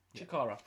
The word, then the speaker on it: Chikara: Versus Brody Lee, again, Luke Harper, in a cage match at, now I apologise if this isn't how you pronounce it, but Chikara. – Yeah. – Chikara.